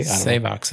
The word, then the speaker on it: Savox: Um, Savox I think I'm pronouncing that correctly. – Savox is what I Savox, thought it was.